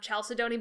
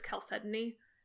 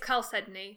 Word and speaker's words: chalcedony: We played a game where we named a character this and pronounced it Chalcedony, but I'm pretty sure it's Chalcedony. – Okay. – It's Chalcedony. – Great. – Oh, Chalcedony is an alternate accepted. – Okay, Chalcedony. Yes. Yeah.